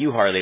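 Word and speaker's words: Euharlee: You said U Harley, right? – Mm-hmm. Yeah, and it's spelled with an E.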